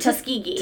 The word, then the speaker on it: Tuskegee: Tuskegee.